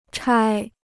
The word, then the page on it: 拆: chāi